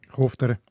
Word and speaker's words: Hochdorf: Hochdorf, wie heisst es? Hofdorf. Richtig. Oder Buchrein.